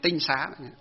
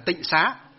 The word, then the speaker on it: tinh xá: Chữ tinh nó có chữ bộ mễ và bộ thanh, bộ mễ với bộ thanh, nên chữ tinh xá. Sau này họ đọc là tịnh xá, họ đọc là tịnh có nghĩa là sạch sẽ, nhưng thực tế trong nó bước đầu gọi là tinh xá.